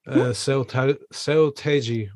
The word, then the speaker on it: Seo Taeji: So, you fuck it, Seo Taeji, or you said it just right.